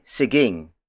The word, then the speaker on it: siging: This lesson is brought to you by Siging.